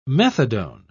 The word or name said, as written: methadone